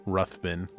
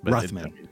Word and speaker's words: Ruthven: And I just start, like, have you heard a name on the street called Lord Ruffin? Am I saying that right? Sorry, is it Lord Ruffin? – Ruthven. – Ruthven.